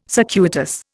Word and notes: circuitous: Indian

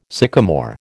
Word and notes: sycamore: American English

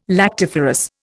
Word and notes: lactiferous: Indian

